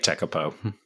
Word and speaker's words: Tekapo: Lake Tekapo.